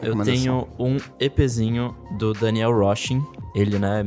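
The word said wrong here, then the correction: Rossen: Daniel é, Ros, é Rosen, né? É. Se escreve Rosen.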